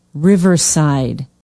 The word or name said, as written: riverside